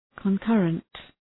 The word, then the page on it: concurrent: kən’kɜ:rənt, kən’kʌrənt